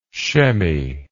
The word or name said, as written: chemmy